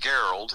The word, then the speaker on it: Gerald: So my real name is Gerald. It's like Gerald, only it's pronounced Gerald, mm-hmm. G-E-R-A-L-D. My last name is Boger, B-O-G-E-R.